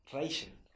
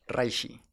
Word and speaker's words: Reishi: Raisin. – Reishi.